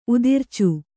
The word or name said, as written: ಉದುರಿಚು